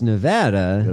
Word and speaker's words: Nevada: Which one is it? – It's Nevada. – Okay. – Is how they say it.